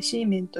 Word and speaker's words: 西門: うんものすごい大きかったすごい楽しかった、うん、あとあれだよねあの西門と書いてシーメンと読む